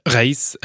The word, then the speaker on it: Ghaees: Comment est-ce qu'on prononce votre prénom et votre nom Raïs al-Shorbaji, en arabe Raïf, donc euh, signifie la première pluie après les sécheresses.